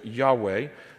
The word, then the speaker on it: Yahweh: Yahweh is one way to pronounce that.